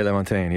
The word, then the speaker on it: LaMontagne: Or was that, because like you did a cover of Ray LaMontagne. That's how you say his name, last name, right? – Ray LaMontagne, Yeah.